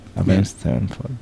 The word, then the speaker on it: Avenged Sevenfold: ¿Avenged Sevenfold o Avenged? – Avenged. – Ah, se pronuncia Avenged. – Avenged Sevenfold.